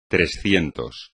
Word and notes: trescientos: espagnol

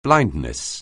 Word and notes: blindness: anglais

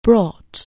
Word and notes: bring: anglais

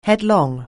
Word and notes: headlong: anglais

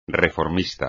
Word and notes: reformista: espagnol

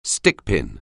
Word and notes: stickpin: anglais

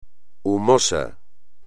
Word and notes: humoso: espagnol

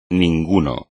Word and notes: ninguno: espagnol

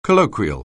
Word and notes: colloquial: anglais

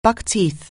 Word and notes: buckskin: anglais

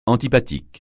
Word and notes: antipathique: francais

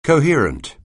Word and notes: coherent: anglais

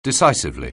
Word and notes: decisively: anglais

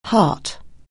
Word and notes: heart: anglais

